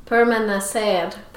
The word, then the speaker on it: permanecer: I was recently made aware that I was mispronouncing my sign-off. – Oh, yes, you were. – Yeah, well, how do you say it? – Permanecer. – Permanecer, and I was saying permanecer. – Correct.